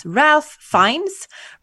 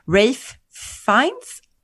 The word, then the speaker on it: Ralph Fiennes: Och Basil Brown spelas av, och jag vet inte hur hans namn uttalas, Ralph Fiennes, Rafe Fiennes.